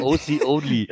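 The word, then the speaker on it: Oconly: O'Conley? Ach, oh. Oh Gott. okay. Zur Betonung, wenn man es direkt so ausspricht, hat er recht. Es heißt aber OC only.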